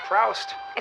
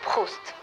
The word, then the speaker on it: Proust: Well, look, it's not Proust. It's pronounced Proust.